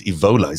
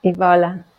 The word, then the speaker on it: Evola: Julius Evola. Is that right? Did I say it right? – Yeah, Evola. – Evola. That's so much better.